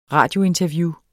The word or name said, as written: radiointerview